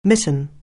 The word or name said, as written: mitten